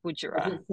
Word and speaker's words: Budjerah: B-U-D. J-E-R-A-H, Bujara.